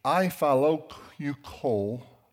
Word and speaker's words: Eyjafjallajökull: It was the Eyjafjallajökull volcano.